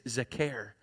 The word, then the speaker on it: zakar: That word there is zakar. Z-A-K-A-R, but it's pronounced Zakar. And I remember it this way because, like, he cares, right?